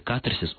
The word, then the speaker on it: катарсис: В традициях русской культуры, насколько я могу представлять себе, как минимум два века, когда, собственно, стали появляться первые орфологические словари, словари трудностей, в слове «катарсис» ударение должно ставиться на первый слог, и большинство орфоэпических словарей именно только как один единственный вариант и фиксируют вот эту вот форму.